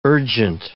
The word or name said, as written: urgent